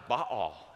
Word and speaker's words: Baal: And that worthless idol that they traded in God for was this god Baal. Hebrews pronounced that way. We say in English, Baal.